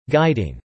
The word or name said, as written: Guiding